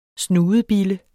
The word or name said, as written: Snudebille